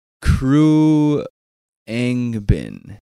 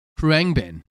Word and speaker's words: Khruangbin: Um, my sister suggested a an album, and I'm gonna butcher the name real quick, but it's Crew Angbin. Khruangbin. Khruangbin.